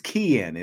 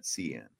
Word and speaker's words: Kian: It is Kian. It is a hard C. Kian, not CN.